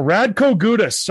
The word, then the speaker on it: Radko Gudas: So Radko Gudas, did I pronounce that correctly? – Yeah, you did it perfectly.